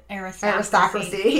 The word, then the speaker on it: aristocracy: Okay. So, aristocracy. There we go. It's the right emphasis on the wrong syllable.